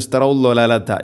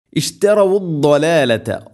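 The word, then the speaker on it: ഇഷ്തറവുദ്ദോലാലത്ത (اشْتَرَوُا الضَّلَالَةَ): വാവിന് ദൊമ്മുണ്ട്, അത് കൃത്യമായിട്ട് പാരായണത്തിൽ കൊണ്ടുവരണം. ഉലഈകല്ലദീന ഇഷ്തറവുദ്ദോ, വാവ് പലപ്പോഴും അവിടെ ഓതിപ്പോന്ന് ഇഷ്തറവുല്ലോലാലത്ത, ഇഷ്തറവുല്ലോലാലത്ത എന്നായിരിക്കും. വാവ് കൃത്യമായിട്ട് അതിൻ്റെ ദൊമ്മ് ഒരു ഉച്ചാരണം ശബ്ദത്തിൽ വരാറില്ല, അത് ശ്രദ്ധിക്കണം. ഇഷ്തറവുദ്ദോലാലത്ത, ദോദ് ഉച്ചരിക്കുന്ന സമയത്ത് നാവിൻ്റെ ഏതെങ്കിലും ഒരു സൈഡ് അണപ്പല്ലുകളോട് നീളത്തിൽ തട്ടിക്കൊണ്ടായിരിക്കണം അത്, അത് ആ ഒരു ശബ്ദം വരേണ്ടത്.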